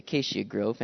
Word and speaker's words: Acacia Grove: That's how you say it, Acacia Grove.